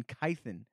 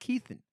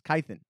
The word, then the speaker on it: Kythen: Kevin Kythen. It's not Keithen. It's Kythen, everybody. – I don't like that. – Get it right. It's spelled Keithen, pronounced Kythen. Don't ask questions.